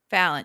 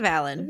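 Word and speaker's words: Valyn: It's V-A-L-Y-N. – Valen. Yeah, Valen. – Valen. – Yeah. Valen. – Valen? What did you think it was, Bridget? – I thought it was Valen.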